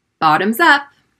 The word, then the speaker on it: bottoms up: Repeat after me bottoms up, bottoms up, bottoms up. Notice how the double T in the middle sounds like a D sound. Bottom, bottom, bottom. Stress the first syllable.